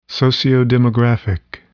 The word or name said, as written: sociodemographic